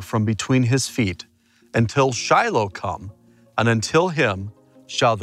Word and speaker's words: Shiloh: I absolutely love being at this site here in Shiloh, or as the proper pronunciation is, Shiloh.